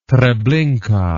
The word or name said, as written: Treblinka